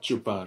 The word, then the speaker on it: chupar: Is that how I say it? Chupar. Uh-huh. Chupar.